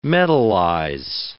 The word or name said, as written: metallize